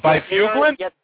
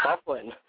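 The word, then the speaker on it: Byfuglien: You mean Bifuglin? – Bifuglin, yeah. When I first saw his name, I thought his name was Bifuglin, then I hear him pronouncing it Bufflin.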